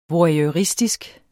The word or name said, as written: voyeuristisk